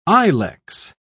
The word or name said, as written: ilex